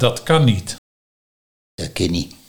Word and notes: Da kin nie: Nieuw lekkerlands

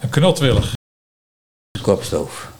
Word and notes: kopstoof: Nieuw lekkerlands

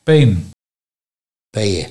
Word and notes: Peehu: Nieuw lekkerlands

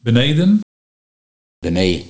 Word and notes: Benee: Nieuw lekkerlands

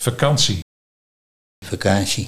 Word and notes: Vekansie: Nieuw lekkerlands

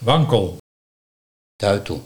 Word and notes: Tuitel: Nieuw lekkerlands

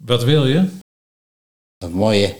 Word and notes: Wa moh je: Nieuw lekkerlands

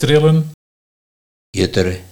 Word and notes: Jutteren: Nieuw lekkerlands

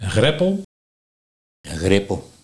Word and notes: Grippel: Nieuw lekkerlands

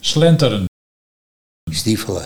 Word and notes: Stiefelen: Nieuw lekkerlands

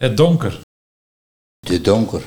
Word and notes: De donker: Nieuw lekkerlands

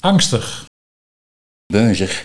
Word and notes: Bunzig: Nieuw lekkerlands

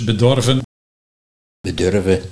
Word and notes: Bedurve: Nieuw lekkerlands

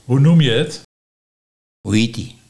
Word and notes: Hoe hiet ut: Nieuw lekkerlands

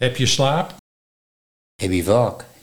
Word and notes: Heb-ie vaak: Nieuw lekkerlands

